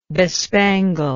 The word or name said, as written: bespangle